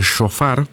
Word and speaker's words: shofar: Y el enfoque recae en las plegarias y en los cantos, y también en el sonido del shofar, que es un instrumento.